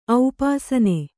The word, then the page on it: ಔಪಾಸನೆ: aupāsane